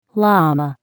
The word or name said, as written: llama